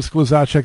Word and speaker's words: Skluzacek: Well, Julianna Skluzacek, it's really nice to talk to you, Julie. And you know what? And you pronounced my name right. I'm very proud of you.